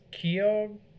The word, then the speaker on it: Keogh: Yeah, it's Miss Keogh. – Miss Keogh. – Yeah, it's like K-E-O-G-H.